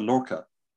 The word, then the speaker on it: Lorca: Catherine Green says, I really like the Lorca.